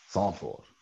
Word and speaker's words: Zandvoort: Can you pronounce it in Dutch? – What, Zandvoort? – Yeah, there you go. Zandvoort.